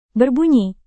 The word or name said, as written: berbunyi